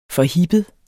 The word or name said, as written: forhippet